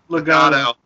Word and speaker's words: Legado: Or Legado, I don't know. Legado. – I said it right, hell yeah.